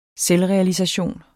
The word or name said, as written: selvrealisation